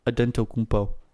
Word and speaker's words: Antetokounmpo: Antetokounmpo. That's what his pronunciation says on Basketball Reference.